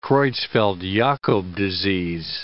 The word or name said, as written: Creutzfeldt-Jakob disease